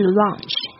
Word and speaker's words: launch: 火箭就是 rocket rocket，发射就是 launch L-A-U-N-C-H，这个 A-U 跟刚才讲的 astronaut 那个 A-U 的发音是一样的的声音，所以你要小心发这个声音，因为有很多人会讲成 lunch 就是午餐了，发射是 launch，你的嘴巴稍微就是上下稍微打开一点，要离开要大一点哦。